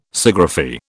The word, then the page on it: Sciagraphy: British English